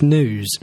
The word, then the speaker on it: gnus: Anyway, GNUs, I suppose, if you're going to say the spelling, although, to give you the correct pronunciation, it's news.